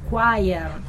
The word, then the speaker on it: choir: L'unica parola in inglese stranissima che è scritta choir si legge choir.